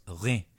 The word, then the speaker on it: Reims: Reims is pronounced Reims, outside of Champagne or in the Champagne region.